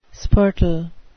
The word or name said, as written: spurtle